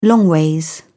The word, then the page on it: longways: British English